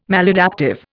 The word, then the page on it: maladaptive: Indian